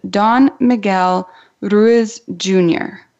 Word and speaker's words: Don Miguel Ruiz Jr.: Now, we have a special guest with us today, and he is Don Miguel Ruiz Jr. Now, did I say that correctly? – Yes, I do believe so, yes.